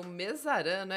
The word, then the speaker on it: Mezzarano: Hoje eu estou com o meu super convidado ao vivo aqui, o cardiologista Dirceu Mesarano, é isso, né? – Mesarano, isso.